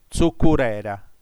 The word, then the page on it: tzucurera: tsu.cu.rè.ra; [tsuku'ɾɛɾa]